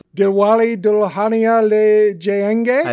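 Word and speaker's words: Dilwale Dulhania Le Jayenge: Diwali Dulhania Le Jayenge.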